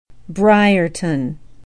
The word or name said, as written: briarton